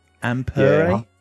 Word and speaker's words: Ampere: Ampere? – Yeah.